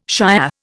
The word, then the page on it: Shiah: Indian